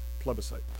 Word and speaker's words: plebiscite: I believe the correct pronunciation is plebiscite.